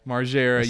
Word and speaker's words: Margera: Margera.